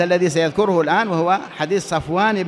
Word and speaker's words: صفوان: أما الراوي صفوان بن عسال، صفوان هكذا كما تسمعون هذا نطقه، صفوان بصاد مفتوحة فاء ساكنة واو ألف نون، صفوان.